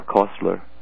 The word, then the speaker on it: Koestler: We know that Arthur Koestler, or Koestler, depending how you pronounce it, which is K-O-E-S-T-L-E-R, he worked for Stalin as an officer, and he helped starve the people in the Ukraine.